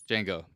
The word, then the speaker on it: Django: Django. The D is silent, like djembe.